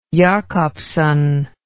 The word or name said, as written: Jacobsen